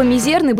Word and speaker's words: мизерный: Слово «мизерный» было образовано от французского аналога и пришло к нам в русский язык с ударением на втором слоге. А во французский язык слово пришло из латыни, а латинский корень требовал ударения на первый слог. Впрочем, в русском языке долгое время была приемлема исключительно норма с ударением на «е» – «мизерный».